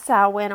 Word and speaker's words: Samhain: So Samhain, spelled S-A-M-H-A-I-N, is pronounced Samhain or Samhain, depending on where you're from.